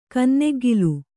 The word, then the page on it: ಕನ್ನೆಗ್ಗಿಲು: kanneggilu